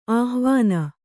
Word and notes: ಆಹ್ವಾನ: āhvāna

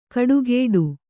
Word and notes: ಕಡುಗೇಡು: kaḍugēḍu

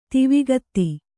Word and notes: ದೈವಿಕತೆ: daivikate

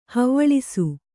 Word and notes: ಹವ್ವಳಿಸು: havvaḷisu

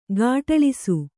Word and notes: ಘಾಟಳಿಸು: ghāṭaḷisu

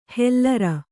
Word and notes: ಹೆಲ್ಲರ: hellara

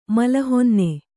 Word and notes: ಮಲಹೊನ್ನೆ: mala honne

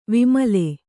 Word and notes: ವಿಮಲೆ: vimale

